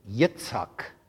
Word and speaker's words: Yitzhak: When Isaac, or as it's pronounced in Hebrew, Yitzhak.